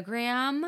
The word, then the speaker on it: Gram: It's a Maria Graham. – Uh-huh. – And Graham is spelled G-R-A-M.